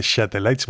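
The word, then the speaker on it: Şatellites: ¿Por qué dice Shatelites? Bueno, porque es que la S tiene un rabito. Y en la ortografía turca, cuando una S tiene un rabito debajo, como una cedilla, pues se pronuncia She.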